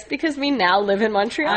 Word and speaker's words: Montreal: Just because it rhymes with the word small. – Just small, Montreal, yeah. – It rhymes with small, so we choose that city.